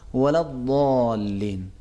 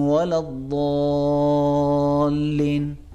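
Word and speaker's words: ولا الضالين: اب اگر کسی اور فرد کے سامنے بھی میں کہوں غیر المغضوب علیہم ولد دالین تو کہیں گے اس نے غلط تلفظ کیا. اب میں دونوں طریقوں سے جو صحیح طریقہ ہے وہ بھی اور جو غلط طریقہ ہے وہ بھی میں دونوں میں پڑھتا ہوں.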